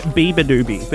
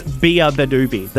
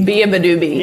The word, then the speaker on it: Beabadoobee: I was saying Bia Badoobie, but it's Bia Badoobie. The is a really... – Bia Badoobie. – Yeah, it's a really critical part of it.